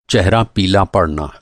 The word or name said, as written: चेहरा